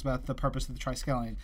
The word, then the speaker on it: Triskelion: Listening to episode 221, just wanted to say Black Panther explains the purpose of the Triskelion in the... Did I say that right? – Yeah.